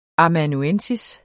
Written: amanuensis

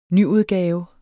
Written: nyudgave

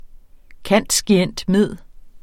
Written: cand.scient.med.

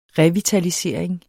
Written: revitalisering

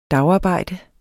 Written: dagarbejde

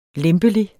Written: lempelig

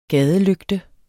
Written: GADELYGTE

